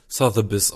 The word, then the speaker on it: Sotheby's: Aber es wird verkauft oder versteigert, kann man ja eigentlich fast sagen, über, ja, Sotheby's? Ja, genau. – Also laut Lautschrift wird es Sotheby's ausgesprochen.